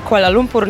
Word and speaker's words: Kuala Lumpur: Oczywiście bardzo dobrze to wymawiasz, Kuala Lumpur, nazwa aż znowu nie jest taka trudna.